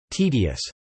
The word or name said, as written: tedious